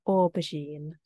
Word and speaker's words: aubergine: In the UK, we use the French word, we say aubergine.